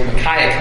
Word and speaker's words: Micaiah: So the messenger who had gone to call Micaiah spoke to him, saying, by the way, I looked at the pronunciation, it's both ways. Micaiah comes out of my mouth more often, but there's no telling how I pronounce it as we go on.